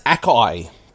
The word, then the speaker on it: Akai: I have to say, it's pronounced Akai, by the way.